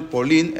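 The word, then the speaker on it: Polín (פולין): Incluso en hebreo, Polonia, no sé si saben cómo se dice Polín. – ¿Cómo? – Polín. Polín. Polín, así se dice en hebreo.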